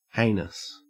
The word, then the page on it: heinous: Australian English